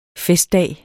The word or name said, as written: festdag